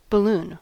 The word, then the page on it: balloon: American English